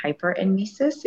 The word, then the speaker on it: hyperemesis: And then I was dehydrated and not able to keep anything down and was diagnosed with hyperemesis, if I'm saying that correctly.